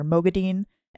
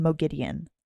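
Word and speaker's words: Moghedien: So the two pronunciations that we get in the audiobooks are Mogadine and Mogideon. I'm sure either or, but they start off with Mogadine and then they switch to Mogideon.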